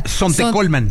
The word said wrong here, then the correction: Sontecomatlán: Sontecomatlán. – Ay, mira, hasta lo dije mal.